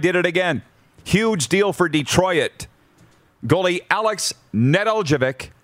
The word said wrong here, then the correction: Nedeljkovic: Nedeljevic? How do you say it? Nedeljkovic. From Carolina to Detroit for a third, and Jonathan Bernier's unrestricted free agent rights. Nijelkovic, thank you.